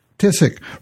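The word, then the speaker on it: Ticic: Well, congratulations on your new 2012 book, Unlocking the Emotional Brain, which you've co-authored with Robin Tysik. Am I saying her name right? – Tysik.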